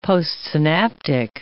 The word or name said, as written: postsynaptic